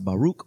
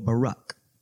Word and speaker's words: Baruch: His name can be pronounced Baruch or Barak.